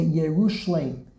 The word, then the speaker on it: Yerushalayim: When we pronounce it in Hebrew, there are two differences. In Hebrew, we say under the yud, we say there's a shva, two vertical dots. Yerushalayim. Under the lamed is a patach, la, and we insert a yud there, and we put a little dot under the yud as a tsere, as a chiruk, Yerushalayim.